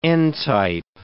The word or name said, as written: n-type